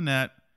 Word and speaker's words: Minnette: Dylan Minnette, yeah.